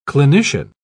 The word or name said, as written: clinician